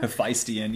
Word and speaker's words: Hephaestion: Hephaestion, yeah.